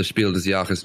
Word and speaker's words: Spiel des Jahres: The day after the Spiel des Jahres nominations were announced, am I pronouncing it okay, Oliver? – That's perfect, yeah.